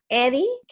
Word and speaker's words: Edye: Eddie, que se escribe E-D-Y-E, es una plataforma que ha estado en Latinoamérica y hasta ahora ha llegado a los Estados Unidos.